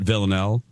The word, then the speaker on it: Villanelle: Is that how you pronounce it? Villanelle? – Yep.